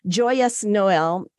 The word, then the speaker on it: Joyeux Noël: The other one I want to see this year, it's French, but I'm going to pronounce it in English, Joyeux Noël.